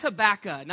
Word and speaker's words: Habakkuk: It's pronounced Habakka, not Habakkuk.